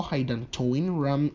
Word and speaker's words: hydantoin: See how hydantoin rhymes with phenytoin.